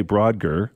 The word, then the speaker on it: Brodgar: The Ring of see, if I look at the word, I mispronounce it. But if I just say Brodgar without looking at it, I'm fine. So it's the Ring of Brodgar, Orkney Islands in Scotland.